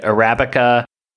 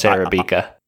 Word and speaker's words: Arabica: Because, you know, I think Nick was pronouncing it Arabica. And there were like three different pronunciations, but yeah, no, it's Arabica.